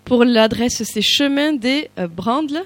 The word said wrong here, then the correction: Brandise: c'est ça? – Chemin de Brandise à Thiel, 31 530. – Précision importante et je l'ai mal prononcée. Merci Camille Simon pour la rectification.